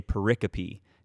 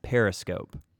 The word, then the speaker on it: pericope: The literary form is known as a pericope, P-E-R-I-C-O-P-E. I read it from my notes and pronounced it as periscope.